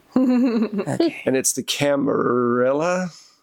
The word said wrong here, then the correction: Camarilla: Am I saying that right? I'm not saying that right. – It's like weird that... – Camarilla. – Okay. Tappy went on a whole thing. – It's Camarilla. – Thank you.